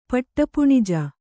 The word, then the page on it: ಪಟ್ಟಪುಣಿಜ: paṭṭa puṇija